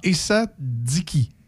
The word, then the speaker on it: Essadiqi: Nadia Essatigui. Esatigi, je sais pas si je le dis bien. S -S, ça s'écrit E-S-S-A-D-I-Q-I. Alors, ah, Essadiki mm-hmm.